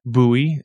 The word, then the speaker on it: Bowie: But yeah, so this week's episode, I sit down with Rachel Bowie, pronounced like Drambuie.